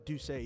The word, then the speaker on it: D'Ussé: Yeah, and so, and another company like uh, D'Ussé, I hope I'm saying it right. – Uh, D'Ussé, yeah.